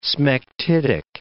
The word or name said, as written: smectitic